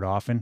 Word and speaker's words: often: Another thing that bugs me is hearing people pronounce the T in often. The T is supposed to be silent in the word often for the same reason it is silent in the word listen, unless you're talking about a boxer named Sonny.